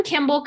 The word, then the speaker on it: Kimball: Well, it's Kim Kimball Coaching all, dot com, all one word, and Kimball is spelled K I M B A L L.